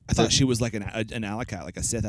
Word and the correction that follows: acolyte: Acolyte. Sorry, not alley cat. Sorry, I said it wrong.